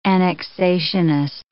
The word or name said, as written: annexationist